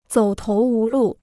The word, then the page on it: 走投无路: zǒu tóu wú lù